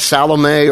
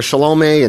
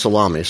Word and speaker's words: Salome: It's pronounced different ways, Salome or Salome, and it's just not pronounced salami, so whatever, you deal with that.